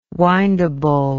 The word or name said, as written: windable